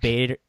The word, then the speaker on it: Bader: You know, how do you say this guy's name? – Diedrich Bader. Yeah, Bader setting up as Batman.